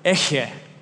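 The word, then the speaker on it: Eche (אֶהְיֶה): You can pronounce this word, Eche.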